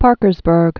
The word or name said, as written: Parkersburg